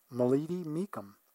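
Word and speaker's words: Muleady-Mecham: This is a Grand Canyon oral history interview with Nancy Mulleady Meekum. And that's spelled N-A-N-C-Y-M-U-L-E-A-D-Y hyphen M-E-C-H-A-M.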